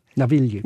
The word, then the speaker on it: Navigli: Navigli.